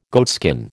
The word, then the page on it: goatskin: American English